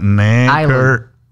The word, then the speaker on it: Necker: N-E, como si fuera cuello. Neck. – Ajá, neck. – N-E-C-K. – N-E-C-K-R. Ajá, Necker.